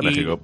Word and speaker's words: México: Y de hecho, se pronuncia México. Pero se escribe México.